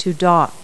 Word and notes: dock: verb

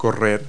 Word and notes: run: verb

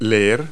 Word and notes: read: verb

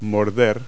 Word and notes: bite: verb

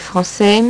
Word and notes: francais: French

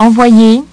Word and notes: envoyer: French

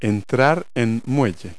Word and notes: dock: verb

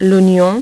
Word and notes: l'oignon: French

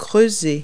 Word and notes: dig: verb

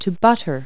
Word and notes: butt: verb

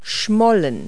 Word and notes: pout: verb

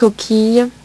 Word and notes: coquille: French

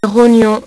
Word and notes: rognon: French

